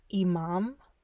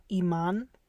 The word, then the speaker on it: imam: It's imam, not iman. Imam with an M.